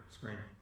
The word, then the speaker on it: Skrein: Sure, Skrein.